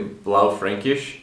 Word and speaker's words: Blaufränkisch: I would say Blaufränkisch and Zweigelt. – Yes, that was much better than my pronunciation.